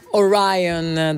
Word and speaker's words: Orion: Ci siamo appena goduti questi 8 minuti e passa di Orion dei Metallica. Questa è la pronuncia corretta.